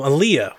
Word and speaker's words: Olija: Um, Aaliyah, which is spelled Olija, but it is pronounced Aaliyah, is the next game from Devolver, and it is uh, mm.